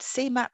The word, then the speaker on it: CMAP: So you work predominantly with us in commercial property finance now, but you're also a qualified CMAP, is that how you pronounce it, mortgage advisor? – Correct, yes.